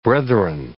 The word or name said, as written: brethren